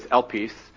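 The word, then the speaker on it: elpis (ἐλπίς): And in the New Testament, in Greek, the word is elpis. And I only share that because I remember the mnemonic device from when I took Greek, that it sounds like the word Elvis.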